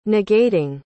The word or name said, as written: negating